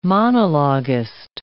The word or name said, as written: monologist